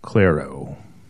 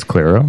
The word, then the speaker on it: Clairo: C-L-A-I-R-O. Claro. That's Claro. Yeah, Claro. – There. See, now this is where the problem lies. Because therein lies the problem. – It's not Claro.